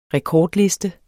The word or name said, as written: rekordliste